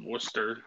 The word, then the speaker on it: Worcester: It's like how Massachusetts has Worcester and there's an R there for no reason.